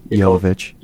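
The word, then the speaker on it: Jovovich: It seems like they might all be unactivated Milla Yakoviches. Did I pronounce that right? – Yovovich. – Yovovich. I'm sorry.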